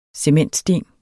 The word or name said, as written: cementsten